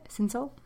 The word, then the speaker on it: Cinsault: Is that how you say it? Cinsault? – Yeah.